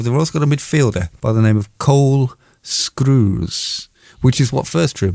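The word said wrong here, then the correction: Skuse: It's spelled nothing like it sounds because I'm pronouncing it wrong. Skews, Cole Skews. Sorry, I was giving you an extra R. It's S-K-U-S-E, which I didn't even think's a name for a human animal, but there he is.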